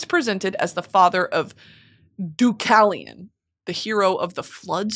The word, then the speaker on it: Deucalion: So this is Deucalion. There's no L. Happy. Deucalion. Fuck!